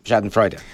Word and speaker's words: Schadenfreude: Although we've adopted the German word, I'm going to probably mispronounce it, Schadenfreude. – Schadenfreude. – Yeah. Schadenfreude. Okay.